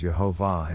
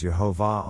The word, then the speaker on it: Yehovah: Yehovah is used over 6,000 times in the Old Testament manuscripts and has been found to be pointed as Yehovah in 2,363 different manuscripts. So, we know how God's name is to be pronounced, and saying God's name as Yehovah honors Him as the Creator God.